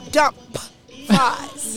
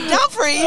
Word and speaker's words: Dumfries: So we moved to Prince William County, and we moved in an area, when I first started as a military wife, that I used to call dump fives. – Yes. – But we moved to an area called Dumfries. Learned how to pronounce it.